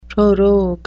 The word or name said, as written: prorogue